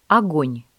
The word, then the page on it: огонь: Russian